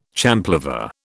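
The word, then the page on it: champlevé: British English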